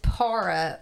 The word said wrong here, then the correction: paura: or fear in Italian. – Oh, paura.